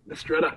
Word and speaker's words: Mistretta: Did I pronounce that correctly, Joey? – That's correct. Yeah, it's pretty good. – Okay. – Mistretta.